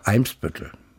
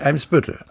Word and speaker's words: Eimsbüttel: Aha weil im Norddeutschen eigentlich auf der ersten Silbe betont wird. Es gibt natürlich Ausnahmen. Ortsnamen doch auf der zweiten Silbe. Eimsbüttel. Ja, gut. Nicht Eimsbüttel.